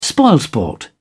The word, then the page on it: spoilsport: British English